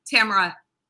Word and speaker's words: Tamara: Tamara.